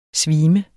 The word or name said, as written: svime